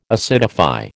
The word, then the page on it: acidify: American English